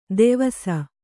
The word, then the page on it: ದೆವಸ: devasa